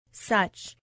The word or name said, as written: such